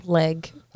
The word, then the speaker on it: Legge: Now, now is it leg? Is or is it? How do you pronounce the last name? – Leg. – Okay, that's what I thought.